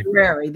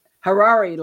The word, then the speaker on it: Harari: Harari, that's it. He was a guest. Harari, like the Ferrari, only with an H.